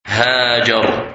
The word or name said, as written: Hajar